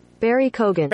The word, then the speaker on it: Barry Keoghan: Just to let everybody know that, yes, I am pronouncing it correctly. You probably would pronounce it incorrectly. You did not do the research, as I have done. So here's your proof. – Barry Keoghan.